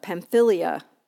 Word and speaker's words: Pamphylia: Parthians, Medes, Elamites, residents of Mesopotamia, Judea, Cappadocia, Pontus, Asia, Phrygia, Pamphylia. Yeah, I said that right.